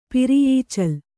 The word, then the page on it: ಪಿರಿಯೀಚಲ್: Kannada